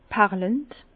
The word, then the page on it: perlend: Bas-Rhin; Herrlisheim